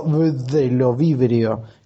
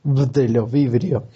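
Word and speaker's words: Bdellovibrio: que es el género Bdelovibrio, con B al principio, Silent B, Bdelovibrio.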